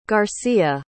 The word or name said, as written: garcia